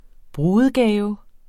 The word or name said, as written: brudegave